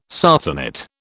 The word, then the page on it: sarcenet: British English